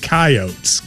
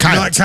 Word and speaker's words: Coyotes: Wait, but why am I pronouncing it Coyotes? – That's how they pronounce it. Coyotes. – Not Coyotes. – No, they pronounce it as Coyotes.